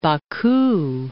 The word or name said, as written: Baku